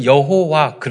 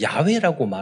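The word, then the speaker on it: Yahweh: 그런데 지금은 과거에는 여호와 그렇게 말을 했는데 지금은 야외라고 말을 합니다.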